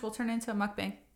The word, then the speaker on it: mukbang: We were thinking about doing a mukbang. – Yeah. You said it correctly. Us Americans say mukbang. – Because mukbang. I think it's because, you know, in Korean it's mogo, mok, eat, pang. It's a room.